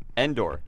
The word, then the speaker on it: Endor: So Frank has put a lot of indoor awesome work forest. Into this. – Yeah. – Did I say that right? Indoor forest? – What? – Did you say Endor? – Endor.